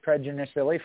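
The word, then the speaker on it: Pedroncelli: And then we got one of the Pedronicillis, PJ Pedronicilli from Sonoma, California, in the cell phone number 33 Chevrolet. – Yeah, you're adding an extra syllable there. It's Pedronicilli.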